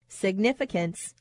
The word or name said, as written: significance